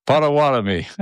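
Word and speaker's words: Pottawattamie: Potawatomi.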